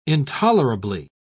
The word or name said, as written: intolerably